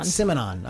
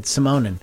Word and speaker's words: Simonon: Simonon. I'm saying that wrong. I almost said Simonin.